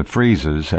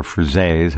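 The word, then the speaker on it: frieze: It almost seems to me, looking at the depictions and the friezes and or frizes, however you want to pronounce it, that the technology required to construct these things far exceeded that which the depictions suggest.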